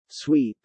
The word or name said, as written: sweep